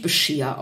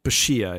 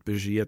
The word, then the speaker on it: Bashir: Äh, haben, haben, wir jetzt gerade unseren Bajor Bajor Moment, weil spricht man ja. den nicht Bashir aus? Bescheer, ja, Bescheer. – Ah, okay, gut, okay. – Ich, ich neige dazu, die deutsche Synchronisationsaussprache zu verwenden und Bashir zu sagen.